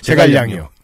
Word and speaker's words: 제갈량: 저는 도대체 그 쯔게량이었나요? 쯔게량. 네. 도대체 이거는 어떻게 읽어야 되는가? 그게 누구예요? – 제갈량이요.